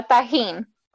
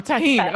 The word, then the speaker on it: tagine: It's a tagine. – Oh, tagine.